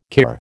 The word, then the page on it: kir: American English